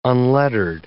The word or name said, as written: unlettered